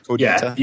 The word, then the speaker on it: Codita: This the Codita. Am I pronouncing it right, too? – Codita. Yeah, yep. – Okay. – Just like Codita. So Codita.